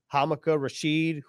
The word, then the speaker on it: Hamilcar Rashed: And then you even talk about guys like Hamaka Rashid, whose name is spelled Hamilcar Rashed, one of the great mysteries here on the Jets roster.